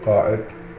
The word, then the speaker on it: Qa'id: Every large caravan was headed by Qa'id, pronounced Qa'id in Arabic.